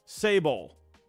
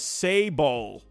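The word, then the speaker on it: Sabol: How do we say it? Sable. Oh, okay. So I was close on the first one. I still didn't get it right. I was saying Sable. Is it Sabal? Sebel? Sable? So I'm saying it right. I'm looking at the pronunciation, and they have... Let me show you the pronunciation here. It says Sable.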